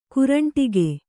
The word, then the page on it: ಕರಂಡಕ: kannada